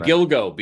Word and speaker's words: Gilgo: Excuse me, Gilgo Beach. Gilgo Beach. Yes. Gilgo. – Gilgo over there and throw a woman in a burlap sack.